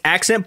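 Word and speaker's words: Ac'cent: Okay, there's like, it's spelled it's Accent, but it's spelled A-C apostrophe C-E-N-T.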